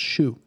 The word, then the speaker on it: Hsu: Stephanie Hsu played the daughter. – It's actually pronounced Shu.